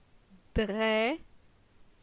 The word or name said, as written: Dre